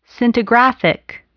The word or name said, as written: scintigraphic